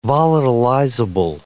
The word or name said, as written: volatilizable